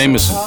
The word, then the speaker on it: JMSN: This is J-M-S-N, Jameson. I don't know how it's pronounced, but it's Jameson. Jameson.